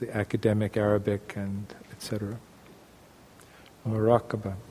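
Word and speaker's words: muraqabah: Maraqabah? – Maraqabah. – Okay. – Yes, thank you. – Let's spell it this way. M-U-R-A-Q-A-B.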